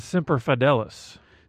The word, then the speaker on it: Semper Fidelis: I think it's Semper Fidelis.